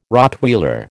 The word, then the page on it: Rottweiler: American English